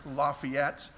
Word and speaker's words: Lafayette: Silly people around here think it's pronounced Lafayette, but if you look on the map, that you would be in North Georgia. Only those of you who went to Covenant College are probably likely to know it's pronounced Lafayette there, and don't make that mistake.